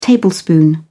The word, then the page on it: tablespoon: British English